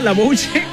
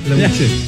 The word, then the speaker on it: La Bouche: ¿Así se dice o no? La Bouche. La Bouche. – La Bouche. – Petit Bouche, ya. – La Bouche.